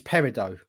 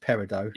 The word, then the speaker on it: peridot: Rossi, it's Peridot, by the way. I've just looked it up. Okay. I believe that's how it's pronounced. Peridot.